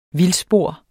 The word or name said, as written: vildspor